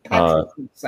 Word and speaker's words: Patroclus: Patroclus, I think. – Yes. – Patroclus.